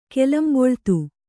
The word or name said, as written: ಕೆಲಂಬೊಳ್ತು